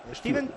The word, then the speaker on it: Stiven: Steven, tal como, bueno, tal como suena. S-T-I-V-E-N, Steven Plaza.